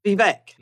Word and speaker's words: Vivek: Vivek. – Vivek.